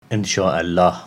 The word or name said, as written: Ensha-Allah